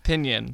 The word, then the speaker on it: pinyin: Pinyin. – Like minion with a P. – The pronunciation key is P-I-N-Y-I-N.